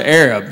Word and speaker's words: Arab: It's spelled A-R-A-B. They don't pronounce it Arab. They pronounce it Arab. Because the original name was Arad, but they misspelled it on the water tower.